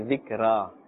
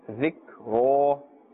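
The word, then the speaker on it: ذِكْرًا: We cannot say zikra. Zikra, incorrect. Zikro.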